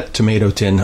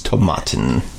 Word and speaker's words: Tomatin: we've been practicing not mispronouncing the name now. – I'm doing it on purpose. Okay. I apologize to the makers. It's Tomatin. – Well, Tomatin was the first scotch that I was introduced to that I got for a Christmas present years ago from our friend Martin.